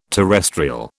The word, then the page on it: terrestrial: British English